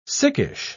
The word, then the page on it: sickish: British English